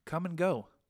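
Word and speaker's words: Kum & Go: Come and go. – Come and go in Denver? – There's a come and go in Denver, and it's spelled K-U-M.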